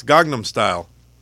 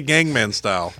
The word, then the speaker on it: Gangnam Style: Incorrect. It's Gangnam Style. He mispronounced it. – It is correct. – I don't think you get to say Gangman Style. – I didn't say Gangman Style. – You didn't say Gangnam Style. You didn't pronounce it right.